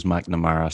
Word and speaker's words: McNamara: Yeah, my second name is McNamara, so I've had them all. I've had McAmara, like McManama, like people pronounce it like it's an Asian second name.